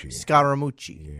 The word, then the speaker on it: Scaramucci: Am I saying that right? Anthony Scaramucci? – Scaramucci. Yeah.